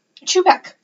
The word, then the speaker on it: Čapek: Chap. Chubek, that's what it's pronounced as.